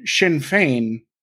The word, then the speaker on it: Sinn Féin: And Sinn Féin, I'm saying that correctly, right? – Mm-hmm. You are indeed.